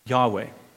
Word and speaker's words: Yahweh: Thanks to the German scholars, we have the J in the Hebrew language, which doesn't really exist. And so they've pronounced Yahweh as Jehovah, okay?